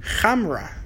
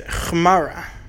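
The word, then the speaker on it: chamra (חמרא): He says, but the way you pronounce donkey is chmara. So chamra is wine and chmara is donkey.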